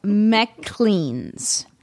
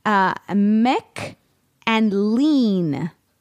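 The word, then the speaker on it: Maclean's: It is Mick and Lean, as in the word, Lane.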